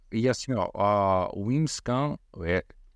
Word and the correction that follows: Whimsical: Eu não sei pronunciar esse nome. É WHIMSICAL.